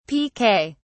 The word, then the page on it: piquet: British English